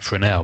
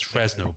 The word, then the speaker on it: Fresnel: So uh, we always pronounced it Fresnel because I believe it's French.